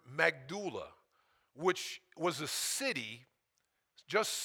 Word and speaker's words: Magdala: Magdala.